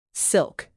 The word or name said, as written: silk